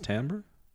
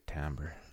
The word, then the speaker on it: timbre: T-I-M-B-R-E. Okay. I would say timbre. Is it pronounced timbre? – Well, hipsters say timbre. – I took a lot of musical theory. – How do you say it? – I would agree with timbre. – Really? – It's timbre.